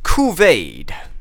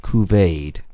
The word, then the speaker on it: Couvade: Couvade. – Couvade. – Couvade. – It sounds French. – It could sound French. It is spelt C-O-U-V-A-D-E. Couvade.